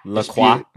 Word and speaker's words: LaCroix: If you want the corporate sponsorship, I do have a Key Lime LaCroix as backup. If I'm pronouncing it right, I know there's a LaCroix. – Yeah.